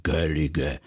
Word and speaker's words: Gaeilge: Wymawiają to Celtowie, Irlandczyk Gaeilge, jeden z języków celtyckich, który zachował się w Irlandii.